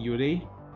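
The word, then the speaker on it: yūrei: Que eles chamam que são os Yuri, Yurei, né? Acho que é pronunciar é assim, Yurei.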